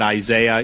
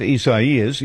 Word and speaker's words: Isaias: I don't know how to pronounce this because it's not normal. So, you know, I'm calling it Isaiah. You guys can call it what you want. I know that's not how you pronounce this. It's something else.